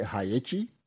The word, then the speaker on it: HAEICHI: And the name of this operation was Operation, I hope I'm saying this right, HAEICHI? H-A-E-I-C-H-I? – I think so.